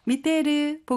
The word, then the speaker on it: 見てる: 테이르는요, 회화체에서는 이가 생략되고 테르와 같이도 발음한답니다. 미테르, 보고 있다, 문이 끝난 형태 뒤에 카라 사용해서 이유원이 나타내고 있고요.